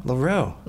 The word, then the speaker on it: Loro: Is it Leroux? – Leroux. – Leroux. – How do you spell it? – L-O-R-O.